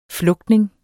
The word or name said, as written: flugtning